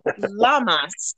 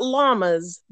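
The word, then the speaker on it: Lammas: It's also pronounced Lamas, not llamas, but Lamas.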